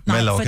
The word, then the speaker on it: Mallorca: Nej, M-A-L-L. – Siger man stadig Mallorca? – Nej, Mallorca. Fordi man siger Mallorca. – Dobbelt L udtales J.